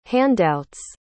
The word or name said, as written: handout's